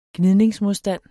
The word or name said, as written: gnidningsmodstand